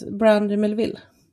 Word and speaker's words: Brandy Melville: Brandy Melvin. Eller Mel... – Ja, precis. Brandy Melville.